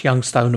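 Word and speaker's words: YXNG Stone: Now Youngstone is spelt YXNG and then Stone, but pronounce Youngstone, of course, which is what I've been doing.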